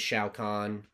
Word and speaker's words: Shao Kahn: You know, this is Liu Kang, this is Raiden, this is Shao Kahn.